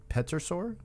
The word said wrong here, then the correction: pterosaur: Yeah, Pterosaur. I think the P is silent.